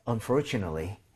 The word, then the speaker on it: unfortunately: 그런데 발음할 때 스펠링도 중요하지만 여기 강세가 세게 갑니다. Unfortunate. 그리고 더 중요한 건 뭐냐면 요런 스펠링이에요. 자, 이거는 여러분들이 암기하거나 쓸 필요 전혀 없고요. ate는 동사 빼고는 다 at이 아니라 at. 이렇게 발음해야 됩니다. 그래서 얘는 네 이게 아니라 네. 네. So unfortunately. Unfortunately 이런 식으로 가줘야 돼요.